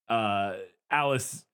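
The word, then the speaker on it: Aelis: And I wanted to know if that aspect came from the book, but then eventually it's revealed that George and Alice, and I always have to remind myself it's Alice because it's spelled in old-timey language. It's A-E-L-I-S, which I assume is where Alice comes from, probably, but it looks like A-list or something.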